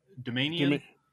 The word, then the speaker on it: Doumanian: Dom- Doumanian.